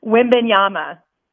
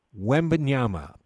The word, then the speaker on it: Wembanyama: Wembanyama. – Wembanyama, Victor Wembanyama.